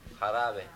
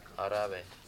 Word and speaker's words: jarabe: The Jarabe, just remember, J's are pronounced with an H. H sound. Jarabe. Jarabe.